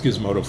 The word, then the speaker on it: Gizmodo: Um, I know it's pronounced Gizmodo, folks, but anyway, we were, we're moving on.